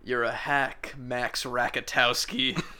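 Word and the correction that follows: Rockatansky: I Rakatansky? – What? Is that how you say it?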